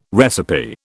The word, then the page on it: recipe: British English